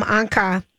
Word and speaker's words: Anka: Anka, rhymes with Bianca, um, about yeah.